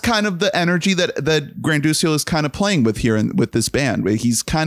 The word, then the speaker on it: Granduciel: So his name is Adam Granduciel. Is that how you pronounce it? Granduciel. – Sure. Yeah.